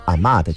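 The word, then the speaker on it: 阿嬷: 就是大家习惯了约定俗成，其实就应该这么来读，所以不要再读成阿木的情书，应该读阿妈的情书。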